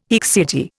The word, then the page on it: haecceity: Indian